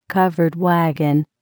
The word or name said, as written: covered wagon